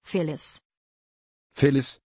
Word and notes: phillis: German

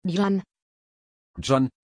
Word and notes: gian: German

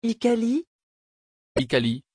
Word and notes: hikari: French

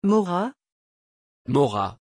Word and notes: Maura: French

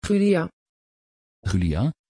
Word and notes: gulia: Dutch

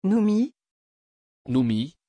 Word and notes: Noomi: French

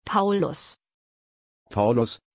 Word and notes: paulus: German

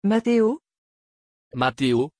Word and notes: Matteo: French

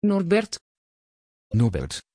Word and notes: Norbert: Dutch

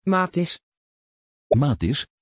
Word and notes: Matthis: Dutch

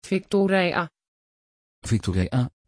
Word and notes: viktorija: Dutch

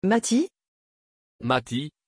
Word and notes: Matty: French

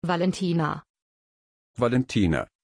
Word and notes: valentina: German

